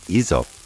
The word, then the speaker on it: hysope: Hyssop in French is pronounced hyssop and spelt H-Y-S-O-P-E.